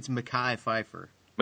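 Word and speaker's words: Mekhi Phifer: It's kind of a mechie. Is that how you pronounce it? McKee? Pfeiffer? – I think it's Mackay Pfeiffer. – Mackay, okay. – That's how I've heard it.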